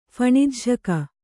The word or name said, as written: ಫಣಿಜ್ಝಕ